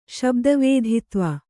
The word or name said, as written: ಶಬ್ದವೇಧಿತ್ವ